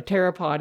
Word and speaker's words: pteropod: They said, no one's going to know what a pteropod is, and they're not going to know how to pronounce it. It's, you know, it's pteropod. Pteropod meaning, you know, flying, like a pterosaur.